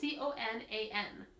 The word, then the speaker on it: Conan: It's like C O N A N. – Conan? – Co- oh, that's how you say it.